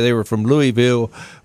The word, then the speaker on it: Louisville: But isn't that how they say it? Is it Louisville? – Yeah, Louisville. And that's one of the ways.